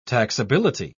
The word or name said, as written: taxability